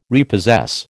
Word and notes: repossess: American English

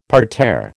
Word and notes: parterre: American English

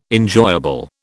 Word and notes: enjoyable: British English

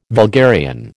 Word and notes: vulgarian: American English

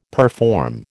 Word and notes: perform: American English

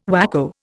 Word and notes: whacko: Indian

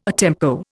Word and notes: a tempo: Indian

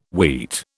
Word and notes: wheat: British English

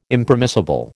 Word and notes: Impermissible: American English

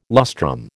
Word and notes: lustrum: American English

